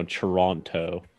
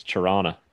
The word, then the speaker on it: Toronto: It's like when people pronounce Toronto, Toronto. No, it's Toronto.